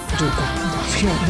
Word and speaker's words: Jukka: Bueno, Jukka, como tal, Jukka, no vamos a salir aquí con los chistes malos. Pero bueno, esa es la pronunciación de finlandés que yo le puedo meter acá.